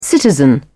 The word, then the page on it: citizen: British English